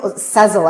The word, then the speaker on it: Sezzle: And so there are a number of different companies, Afterpay, Klarna, PayBright, Sizzle, and you'll Sezzle, I think it's pronounced.